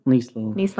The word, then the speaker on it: Nieslanik: Nieslanik.